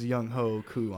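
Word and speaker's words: Younghoe Koo: Minshew mania, free Young Ho or Young Weku, whatever you pronounce it. You know it's Young Ho Ku on this side.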